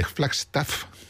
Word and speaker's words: Flagstaff: Bueno, pues el Coalmine Canyon está al norte de Flagstaff, de Flagstaff, uh-huh, así se pronuncia,